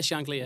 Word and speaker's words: Shankly: Is that how I'm pronouncing it correctly? – Yeah, yeah, Shankly, yeah.